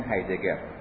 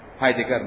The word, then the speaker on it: هایدگر: سومین شخصیت بزرگ در عرصه هرمنوتیک کسی نیست جز مارتین هایدگر. البته اینو هایدگر هم میگن، هایدگر هم گفته میشه، منتها ظاهرا تلفظ صحیح ترش چیزی است نزدیک به همون فتحه.